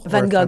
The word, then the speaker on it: Van Gogh: How do you say his name in French? Van Gogh? – Van Gogh. – Van Gogh or? – Yeah, I guess you pronounce it better.